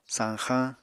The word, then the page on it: sañxañ: Apatani